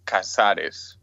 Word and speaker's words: Casares: It's with a C, but actually the sound is like a K, but it's Casares.